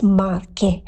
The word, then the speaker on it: Marche: The correct pronunciation is Marche.